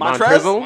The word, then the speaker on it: Montrezl: Montrezl. – Montrezl in 25 minutes.